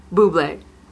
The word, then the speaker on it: Bublé: It always comes out on like, blah, blah. Michael blah. – Bublé.